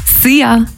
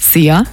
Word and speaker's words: Szia: Szia! Hallo und Tschüss, könnt ihr euch ganz easy merken. Wird einfach ausgesprochen wie unser Energy-Star Szia.